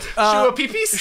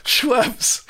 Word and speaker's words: Schweppes: How do you pronounce Schweppes? The soda drink. – Yeah. What was that short you sent? – Shua-pee-pees? – Shua-pee-pees. – Oh, man. I love those are some of the best shorts. Just mispronouncing things. – Shua-pee-pees. Schweppes.